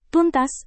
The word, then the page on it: tuntas: indonesian